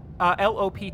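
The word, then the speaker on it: Lopt: Lop. – Lop. – Lop. – Lop. – Lop. – Lop. – Lop. – Is the T silent? – Kind of. – Lop, lop, lop. – Pronouncing that kind of makes your head go backwards a bit when you say it.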